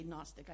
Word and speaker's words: Gnostic: The G is silent when you say Gnostic. I'm not adding a G, I'm adding an A, but the G was silent.